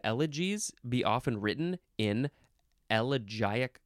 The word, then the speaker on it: elegiac: E-L-E-G-Y. Yes, I said it right. Number one, a poem in elegiac couplets. Elegiac, that's how you say it. Now, some people might want to say elegiac. That's fine, too.